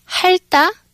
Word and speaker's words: 핥다: And you, even though there's liu and tiat, you only pronounce the liu. And so you say, so you drop the tiat, and in the infinitive form, it's almost like a double D, 핥다, right? – Exactly.